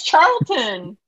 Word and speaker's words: Charlton: Charlton or Charleston? – Charleston Heston. – He's Carlton. – Carlton. Carlton from the Fresh Prince of Bel-Air. No, it's Charlton.